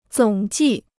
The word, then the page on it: 总计: zǒng jì